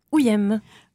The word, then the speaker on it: Houyam: Et son orthographe, sa véritable orthographe, c'est H-O-U-Y-A-M. – Oui, c'est bien ça. – Et ça se prononce Ouyem.